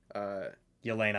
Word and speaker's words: Yelena: Yelena.